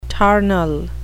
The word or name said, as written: tarnal